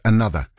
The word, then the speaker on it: ANOTR: Going, despite the fact that the act is spelled A-N-O-T-R, it's pronounced another.